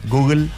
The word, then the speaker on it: Google: Google. – Sí. – Google.